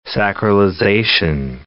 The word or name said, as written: sacralization